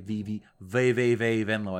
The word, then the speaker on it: VVV Venlo: Of course, VVV Venlo, that's fun to say, VVV Venlo, I believe is how you say it, is in the second division in the Netherlands, a already goal-friendly country to score in.